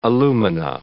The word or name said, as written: alumina